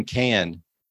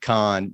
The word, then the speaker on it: Cannes: By the way, see you in Cannes. I got that completely wrong. I said con. You know, it's fun to drink wine. – Yeah, but at least you didn't pronounce the S.